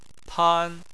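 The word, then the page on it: pan: tone 1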